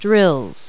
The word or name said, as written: drills